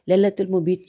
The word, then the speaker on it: لیلۃ المبیت: لیلۃ المبیت کی ہجے کرتے ہیں, لام یہ لام تے لیلت الف لام میم بے یہ تے.